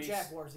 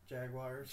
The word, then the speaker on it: Jaguars: And we say the word Jaguars again. – Jaguars. – Jaguars. – You made it like four syllables. Jaguars. – That's how the British say it.